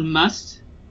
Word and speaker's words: musth: So it's called must. You say it just like the word must, M-U-S-T. – Must. – Yeah, but it's spelled with an H at the end, so M-U-S-T-H.